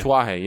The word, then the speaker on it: Tatuaje: Tatuaje, yes.